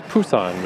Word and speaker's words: Busan: which I learned in Korea, they actually pronounce Busan with a P.